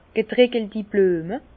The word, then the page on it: getreckelti Blüeme: Bas Rhin; Herrlisheim